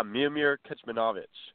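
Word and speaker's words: Miomir Kecmanović: Yeah, Miomir Kecmanovic.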